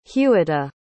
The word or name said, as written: hughetta